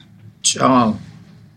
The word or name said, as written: tsaaal